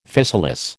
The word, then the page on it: physalis: American English